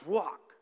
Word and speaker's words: ruach: When this word is pronounced properly in the Hebrew, it sounds like very breathing itself, like ruach.